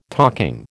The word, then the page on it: talking: American English